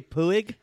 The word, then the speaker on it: Puig: Did you say Puig? There's a Puig. – That's Puig. Like, it's spelt that way, Puig.